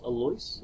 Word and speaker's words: Alois: Prince Alois, am I saying that right? – Yeah.